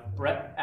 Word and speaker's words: Brett: Brett Atmore, B-R-E-T-T-A-T-T-M-O-R-E.